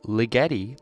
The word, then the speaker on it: Ligeti: Coming up next is going to be San Francisco Polyphony, composed by Georgi Ligeti, which is apparently correct, the correct pronunciation according to members of the Louisville Orchestra.